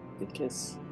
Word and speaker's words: Vikas: It's V-I-K-A-S. – Okay. – I'll put it in the chat for you. – Great. – Vikas.